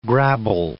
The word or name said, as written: grabble